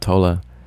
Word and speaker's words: Tolle: Things like, you know, I remember after reading The Power of Now by Eckhart Tolle, however you want to say that. He is German, so you do pronounce the E, Eckhart Tolle.